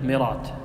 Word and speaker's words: مرات: مرات، هذه معروفة يعني بلدة في نجد تكتب مرات هكذا لكن تنطق مرات معروفة.